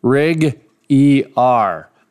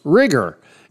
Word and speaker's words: RigER: Of course, I love the name Rig ER. Now, ER is capitalized, but it's pronounced rigger. And When I first saw it, I thought rig ER, but of course I got corrected.